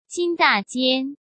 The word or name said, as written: Jin Dajian